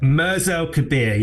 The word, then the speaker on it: Mers El Kebir: For people who've never heard of this story, just tell us then what actually happened at, is it Mers El Kebir? Is that how you say it? – Mers El Kebir, yeah, in Algeria.